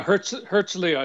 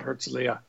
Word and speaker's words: Herzliya: Okay, so Herzliya, is that how you pronounce that? Herzliya? – Yeah, Herzliya. It's named after Herzl, who was the sort of the founder of Israel. So they call it Herzliya.